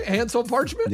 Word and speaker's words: Hansel Parchment: Hansel Parchment? – Yeah, that's right.